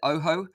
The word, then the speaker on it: Ojo: It's actually pronounced, if you watch the trailer and listen to the guy, he pronounces it the OHO.